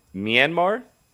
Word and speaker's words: Myanmar: February 1st, there was a coup in Myanmar, if I pronounce that correctly.